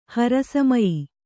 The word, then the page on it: ಹರಸಮಯಿ: kannada